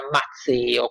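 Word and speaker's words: Mazeo: Mazzeo, con una Z solo si scrive e si pronuncia, che guarda caso gli aprirà poi la porta di Babilonia senza combattere e che da Alessandro sarà confermato satrapo di Babilonia.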